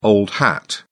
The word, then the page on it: old hat: British English